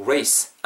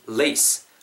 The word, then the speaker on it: lace: I'm not saying race. I mean lace. 레이스라고 발음하지 마시고 일산도 지켜서 레이스 이렇게 발음해 주셔야 되고요.